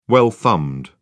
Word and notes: well-thumbed: British English

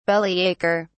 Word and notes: bellyacher: British English

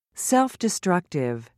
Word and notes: self-destructive: British English

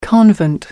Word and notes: convent: American English

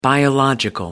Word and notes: biological: American English

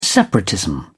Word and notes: separatism: British English

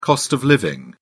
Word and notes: cost of living: British English

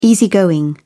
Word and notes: easy going: British English

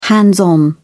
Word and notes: hands-on: British English

